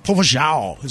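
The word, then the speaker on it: popozão: P-O-P-O-Z-A-O. That's Portuguese for big butt. Popo Zhao is how you say Zhao. Say like the Nunji Prado, Nunji Tino, Popo Zhao. Like that.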